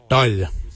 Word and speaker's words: dhaoly: Your quan is spelled G-W-A-N. Quan is your backside. Thigh. We say dal. Dal. Dal is spelled D-H-A-O-L-Y.